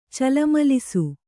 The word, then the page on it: ಚಲಮಲಿಸು: calamalisu